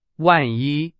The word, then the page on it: 万一: Chinese